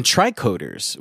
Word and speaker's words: tricorder: Yeah, and tricorders, which are...